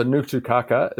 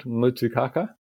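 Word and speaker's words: Ngutu Kākā: Oh, so Ngutu Kaka is the name of the plant. Yeah, it's like an Nga sound, Ngutu Kaka.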